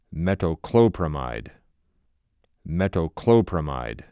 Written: Metoclopramide

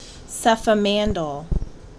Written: Cefamandole

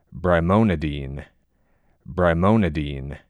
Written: brimonidine

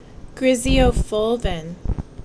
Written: Griseofulvin

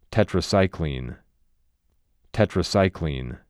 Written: Tetracycline

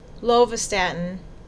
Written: Lovastatin

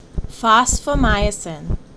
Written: Fosfomycin

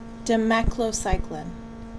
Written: Demeclocycline

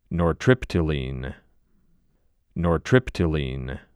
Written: Nortriptyline

